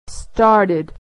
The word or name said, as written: started